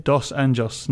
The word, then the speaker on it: Dos Anjos: Dos Anjos. No fancy accents, just as it's spelled.